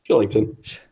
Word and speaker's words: Kylington: K-Y-L-I-N-G-T-O-N. Ton. – Yeah. Looks like Kylington, doesn't it? – It does look like Kylington. Shillington. – Then you remember he's Polish, and how do you pronounce case? – Yeah. – In Poland. Shillington.